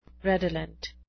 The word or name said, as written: redolent